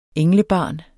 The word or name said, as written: englebarn